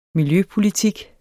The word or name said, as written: miljøpolitik